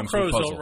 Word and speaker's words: Crozel: Joe Crozel rhymes with puzzle.